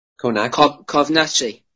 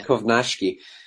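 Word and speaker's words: Kownacki: Kovnacki. Yeah. – Kovnacki.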